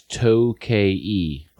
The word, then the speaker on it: Tokaji: But the pronunciation is what looks like Tokaji is To-K-E. – Oh. – Is the way it's pronounced, I assume, in eastern Hungary.